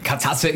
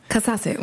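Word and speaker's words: Kazaze: Der treue Bonus wartet immer noch bei uns und in der Zwischenzeit hat sich jemand gemeldet, hat uns aufgeklärt, dass es nicht Katzasse ist, wie wir es ausgesprochen haben, aber wir waren uns da einfach nicht sicher. Haben wir nicht gewusst, wir haben wieder dazugelernt. Wird jetzt richtig ausgesprochen? – Katzasse, und ist natürlich die slowenische Bezeichnung von Edling.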